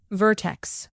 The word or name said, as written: vertex